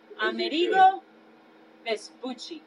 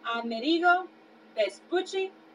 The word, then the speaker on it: Amerigo Vespucci: Amerigo Vespucci. – A couple more times. – Amerigo Vespucci.